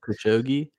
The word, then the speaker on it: Khashoggi: So in 2018, there was a journalist, a U.S.-based journalist of Saudi descent named Jamal, I'm going to butcher the last name, but Khashoggi, I believe was his last name. – Khashoggi. – Yes, thank you.